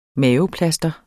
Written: maveplasker

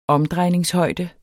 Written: omdrejningshøjde

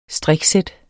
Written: STRIK

